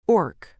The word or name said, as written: orc